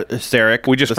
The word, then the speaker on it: Szarekh: Because it's spelled S-Z-A-R-E-K-H. Silent Z, silent H.